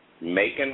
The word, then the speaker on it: Macon: Macon rhymes with bacon, yes.